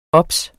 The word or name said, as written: obs